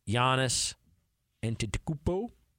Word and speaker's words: Giannis Antetokounmpo: Down to Giannis Antetokounmpo, Nikola Vucevic. – You said it right.